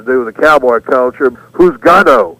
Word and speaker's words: Juzgado: And that word is spelled J-U-Z-G-A-D-O. And, you know, again, kind of like dar la vuelta. We didn't quite get what they were saying. Juzgado, so we turned it into who's gal.